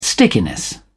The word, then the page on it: stickiness: British English